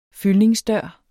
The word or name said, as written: fyldningsdør